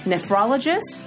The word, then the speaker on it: nephrologist: Nephrologist? Did I say that right? – Yep, that's right.